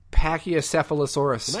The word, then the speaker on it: Pachycephalosaurus: How do you pronounce that? – Pachycephalosaurus. – Nicely done.